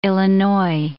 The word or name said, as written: Illinois